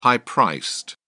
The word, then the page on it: high priced: British English